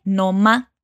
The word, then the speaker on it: no más: Ella dice Noma, y aquí tenemos una reducción de pronunciación nativa de Perú.